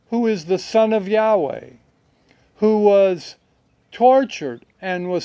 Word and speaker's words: Yahweh: I pronounce it Yahweh. I believe it's Yahweh.